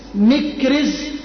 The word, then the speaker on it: مكرز: قال ابن إسحاق: وكان على المشركين يومئذ عكرمة بن أبي جهل، وروى ابن هشام قال: بل كان عليهم مكرز، مكرز بتسكين بكسر الميم وتسكين الكاف، مكرز او مكرز، راء راء رز بفتح الراء.